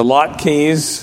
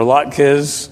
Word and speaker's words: latkes: The latkes, or latkes, that are the potato pancakes deep fried.